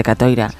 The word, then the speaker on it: Catoira: Catoira, porque Francisco basó su novela, la novela Sur, en precisamente en Catoira, los embarcos de los vikingos por esa zona de Galicia.